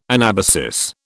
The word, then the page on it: anabasis: British English